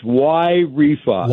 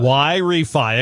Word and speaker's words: Y Refy: Let's start with, how am I pronouncing the company? – You did a great job. It's Y Refi. – Y Refi. It's spelled Y R E F Y. So Y Refi.